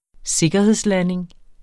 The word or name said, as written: sikkerhedslanding